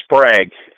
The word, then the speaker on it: Sprague: Ron Sprague, S-P-R-A-G-U-E.